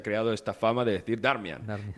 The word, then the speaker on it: Darmian: Eh, por cierto, ahora que has dicho Darmian,